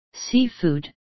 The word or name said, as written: seafood